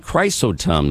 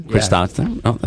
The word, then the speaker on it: Chrysostom: Michelle and Desiree made a one-time pledge in honor of the Chrysostom House. So let's go. – Chrysostom. – Oh, my goodness. Well, so how do you pronounce that? I just butchered that. What was that? – Chrysostom. – Chrysostom.